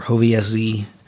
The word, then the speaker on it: Hovězí: So looking specifically at the goulash, the Hovězí goulash, or Hovězí, I think they pronounce the last part. Mm-hmm. They enunciate the Z.